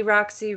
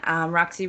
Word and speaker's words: Roxy: You can find me on The Roxy Rouge on Twitter, The Roxy Rouge on Instagram, and then Roxy Rouge Russell on Facebook.